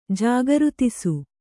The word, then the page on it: ಜಾಗೃತಿಸು: kannada